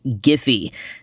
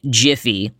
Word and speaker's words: Giphy: the company I was discussing is properly pronounced Giphy, not Jiffy, like the campfire popcorn that I love.